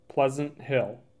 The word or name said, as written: Pleasant Hill